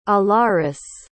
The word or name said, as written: alarice